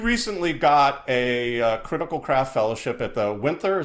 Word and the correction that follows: Winterthur: is that how you pronounce it? – Winterthur. They say it. Winterthur. I'm sorry, Winterthur. I love you. Saying it wrong. – We're trying the best we can. – It sounds like you need somebody English to pronounce it. Winterthur. – Yeah, Winterthur. I think you just nailed it.